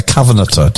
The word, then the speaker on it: covenanter: A covenanter. Did I say that right? – Yep.